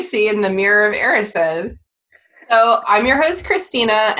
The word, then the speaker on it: Erised: Is that how we're pronouncing it? – Yeah. When I read these books before the movies, before other people told me how to pronounce things, I pronounced a lot of things wrong, and one of them apparently was the Mirror of Erised. – I think, yeah. We're going Erised.